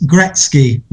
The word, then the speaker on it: Gretzky: He's been taking the mick out of me because I can't say his name. What's he called then? – Gretzky. – Close. Gretzky. Wayne Gretzky.